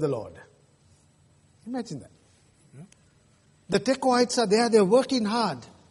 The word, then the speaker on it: Tekoites: The Tekoites. Pronounce it that way.